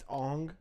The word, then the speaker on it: Aang: They mispronounced names. Everyone was white. – It's Aang. Right? – Sokka's, like, light brownish.